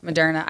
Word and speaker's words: Moderna: Moderna? How you say it? – Moderna.